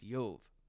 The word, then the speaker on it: Job: And Shekard asserts that this is the root of the name Job, which in Hebrew is pronounced Yov. He claims that a slight metathesis, where the O sound moves from Oyev to Yov, to the first syllable after the Yod, makes the name Job.